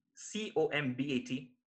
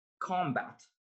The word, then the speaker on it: combat: For instance, I tended to pronounce the word C O M B A T as combat, but that was corrected. It was it's always supposed to be combat, and I didn't know that.